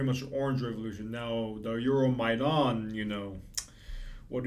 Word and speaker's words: Euromaidan: The Euromaidan Revolution. – Euromaidan. – Yeah, Euromaidan. Euromaidan. – Google it, folks. – Yeah, it's spelled E-U-R-O, Euromaidan, M-A-I-D-A-N, Maidan. Euromaidan. Also known as the Revolution of Dignity,